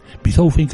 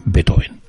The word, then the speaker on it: Beethoven: Beethoven creo que es como lo pronuncian los alemanes, pero nosotros lo vamos a pronunciar siempre a la española. Es decir, le vamos a llamar Beethoven. Beethoven,